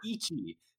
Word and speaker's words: ichi: And then there's like a whole other paragraph to remember how to pronounce it, because you say ichi, so they were saying like, oh, it sounds like itchy.